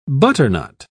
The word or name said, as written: butternut